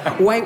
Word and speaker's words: white: So, white where you pronounce the H, white.